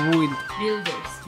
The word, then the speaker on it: Builders: Eh, ¿Cómo se pronuncia? Builders. Aquí arriba. Como Builders. Builders. – Sí, es que decimos build, ¿verdad? build. Eh, vamos a ver cómo sale Fallout 4, 40% de descuento.